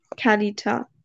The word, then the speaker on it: Kalyta: ähm, mein nächstes ist Kaljata. – Wie schreibt man das? Äh, oder Ka Kalita. Ähm, K-A-L-Y-T-A. Ja, Kalita.